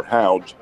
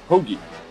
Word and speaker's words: Hoge: Now, you take a look at the last name, and it's H-O-G-E. It looks like it would be Hoge or Houge, but it's pronounced Hoagie.